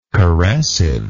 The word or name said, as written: caressively